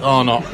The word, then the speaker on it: Arnott: Two newcomers, Tony Waygood and Mike Arnott, two good golfers. – Is that how you pronounce it, Arnott? I didn't know if it was French. – Like the biscuits.